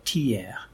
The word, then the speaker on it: Thiers: It's manufactured in an interesting city in central France called Thiers. That's how you pronounce it.